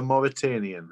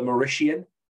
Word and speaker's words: Mauritanian: How do you pronounce that? I've seen it advertised many times, but I cannot. – The Mauritanian? – The Mauritanian. Is that how it's pronounced? – I imagine so. The Mauritanian. I kept saying the Mauritian.